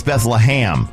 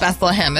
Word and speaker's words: Bethlehem: you're mispronouncing Bethlehem. It's Hem. – It's Ham. Bethlehem. – Bethlehem. It's Beth-LUH-HAM.